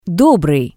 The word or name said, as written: dobrii